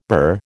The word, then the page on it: bur: American English